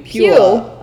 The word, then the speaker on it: Puyallup: It's Pew. Puyallup. Puyallup. – Puyallup. – Puyallup. – Just Puyallup. Right. Up.